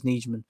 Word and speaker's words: Nijman: Right tackle, Yash Nijman, if you want to say it like Aaron says. And I'm assuming Aaron knows how to say his buddy's name, but he could be wrong too. He says Nijman.